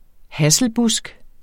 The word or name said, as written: hasselbusk